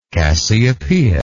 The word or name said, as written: Cassiopeian